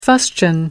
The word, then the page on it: fustian: American English